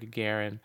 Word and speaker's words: Gagarin: G-A-G-A-R-I-N.